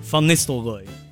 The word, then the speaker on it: Van Nistelrooy: Van Nistelrooy.